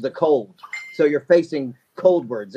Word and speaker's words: Coldwards: And then recently you left Saliva, and you have your new band, Coldward, is how it's pronounced. And to me, it looks like Coldward. It's Cold, W-A-R-D-S. I don't know how someone could pronounce that differently, but when we started the show, you made sure I said it right.